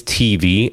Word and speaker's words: TV: My name is TV, as in television, TEEVEE, Aguirre, AGUIRRE.